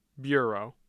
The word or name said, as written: bureau